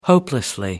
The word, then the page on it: hopelessly: American English